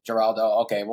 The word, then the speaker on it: Jurado: Jurado.